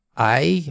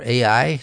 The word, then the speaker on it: Ai: Now, how about Ai, or Ai, depending on how you want to pronounce it.